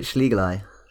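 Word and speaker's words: schlegelii: I always pronounce it Schlegeli.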